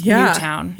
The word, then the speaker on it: Newtown: That's the only reason I knew how to pronounce Newtown. – Yeah. – Because to me, it looks like Newton.